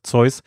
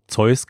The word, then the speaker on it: Zeus: Oder, oh, ja, im Englischen wird Zeus, ich glaube, Zeus ausgesprochen.